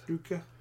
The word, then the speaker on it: Drudkh: Fen, F-E-N. Drutka, how do you say that? D-R-U-D-K-H. Drukith. – Drukith. I think you had it right.